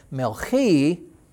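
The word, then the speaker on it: Melchi (מלכי): Melchi then is Melech with that little I sound on the end. Melchi is my king.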